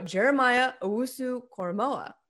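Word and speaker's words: Jeremiah Owusu-Koramoah: You know, Jeremiah Owusu-Koramoa.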